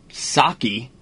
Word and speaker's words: Psaki: Well, let's start off with Jen Psaki, silent P, on D.C.